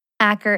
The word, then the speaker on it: Acker: Acker is spelled A-C-K-E-R.